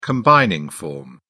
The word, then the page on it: combining form: British English